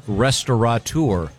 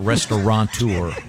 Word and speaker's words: restaurateur: I know that the most mispronounced is restaurateur. – Mm-hmm. – There's no N. It's not restaurateur.